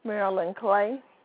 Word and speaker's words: Marilyn Clay: Marilyn Clay. – And your first name is spelled M? – A-R-I-L-Y-N.